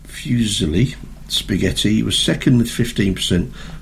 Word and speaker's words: fusilli: Fusilli.